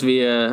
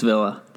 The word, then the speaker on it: Villa: Says Villa. That's right. It's Villa. – Because it sounds like it looks like a Spanish word, but they say it like it's in proper English.